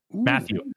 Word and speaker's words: Mathieu: And the matchup I'm looking for is TJ Hockenson versus the Honey Badger, Tyrann Mathieu. Matthew. I always get that name wrong for some reason.